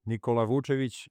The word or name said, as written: Nikola Vucevic